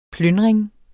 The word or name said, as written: PLYNDRINGERNE